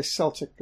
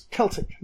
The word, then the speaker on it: Celtic: So there's the Celtic people, and while we spell it with a C, it's pronounced with a K. And if you say Celtic, the people in Wales and Scotland Ireland want to just clap you because no, it's Celtic now. They'll correct you.